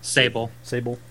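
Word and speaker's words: Sabol: Sable.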